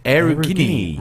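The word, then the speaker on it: Aurigny: How do you pronounce that? – Aerogini.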